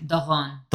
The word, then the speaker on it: Doron: Doron. – Doron. Yeah, I will say to Abigail, oh, it's Doron.